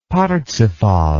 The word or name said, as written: parzival